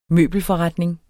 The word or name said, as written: møbelforretning